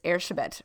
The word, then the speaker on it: Erzsébet: Elizabeth Bathory, or if you want to pronounce her name the Hungarian way, which she is, it's Erzsébet Bathory.